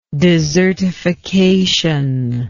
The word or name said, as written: desertified